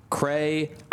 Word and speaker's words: crayon: Webster's dictionary states the proper way to pronounce crayon is in two syllables, crayon.